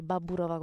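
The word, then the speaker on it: Baburova (Бабурова): Ma a volte facciamo anche azioni di massa, come quando attaccarono Stanislav Merkelov e Anastasia Baburova, che si dice Baburova con la A, anche se si scrive con la O, e Anastasia Baburova nel gennaio del 2009.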